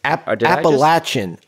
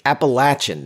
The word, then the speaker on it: Appalachian: Application State? – Appalachian. Appalachian, Nick. – You got it. You got it. We have t-shirts that say that. The pronunciation is in itself a hot take.